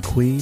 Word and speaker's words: Cui: And of course, you can find me, Brian Kui, my last name is spelled C-U-I, on LinkedIn.